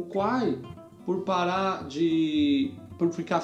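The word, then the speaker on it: Khwai: E em algum lugar falava desse, dessa reserva chamada Kwai, que se escreve K-W-A-I, não é isso? – Não. – Não, errei. K K-H-W-A-I. – Quai.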